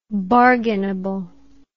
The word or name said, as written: bargainable